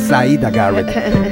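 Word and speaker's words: Siedah Garrett: Así es, y bueno, ya extrañaba la pronunciación perfecta que tienes, porque sí es Syeda Garrett. Tienes toda la razón.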